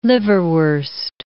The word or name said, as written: liverwurst